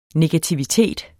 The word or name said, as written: negativitet